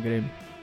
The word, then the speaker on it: Grêmio: falei certo? Grêmio? Grêmio, Grêmio blá, blá, blá, sei lá. É, eu falei, é Grêmio, é.